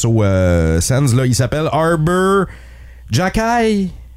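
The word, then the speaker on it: Arber Xhekaj: Écoute, il y a un X, il y a un J, il y a un K là-dedans. Oui, ça se prononce Arber Jackay.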